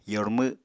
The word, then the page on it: yormw: apatani